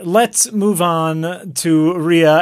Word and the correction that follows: Raya: and the Last Dragon. Raya. Come on.